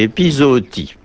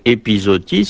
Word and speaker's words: épizootie: On a une tendance naturelle à le prononcer épizootie, c'est plus simple, mais c'est bien zoo, comme dans le nom du jardin zoologique, qu'il convient de dire.